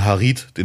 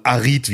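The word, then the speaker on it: Harit: Das finde ich sehr lustig, wenn aus Amin Harid, den wir alle, alle kennen, auf einmal Arid wird, weil man in Frankreich das H nicht ausspricht.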